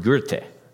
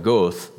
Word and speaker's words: Goethe: There's a man, I never knew how to pronounce his name, and I probably still don't, but it's spelled G-O-E-T-H-E. German man, writer, famous writer, lived back in the late 1700s. His name is Johann Goethe, I believe it's pronounced. I would have just called it Goethe, but that's not right. So I believe it's Goethe.